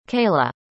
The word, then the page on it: kayla: American English